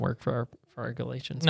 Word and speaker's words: Galatians: It's good time up in here discussing Galatians. That's not easy to say. – Discussing Galatians.